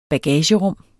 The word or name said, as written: bagagerum